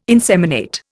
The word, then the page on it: inseminate: Indian